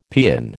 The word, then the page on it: paean: American English